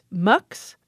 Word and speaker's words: Mx: MX is often pronounced MUX.